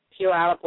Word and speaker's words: Puyallup: Puyallup, Washington.